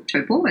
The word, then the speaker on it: Taupo: So I started in Gisborne, went to Taupo or Taupo as the locals pronounce it, worked on the helicopter there as well, did a lot of retrieval into the snow.